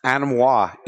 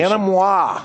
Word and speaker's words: Anemoi: And Animois is the correct pronunciation. – Animois. Mm-hmm. Good.